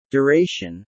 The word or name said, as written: duration